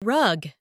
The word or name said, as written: rug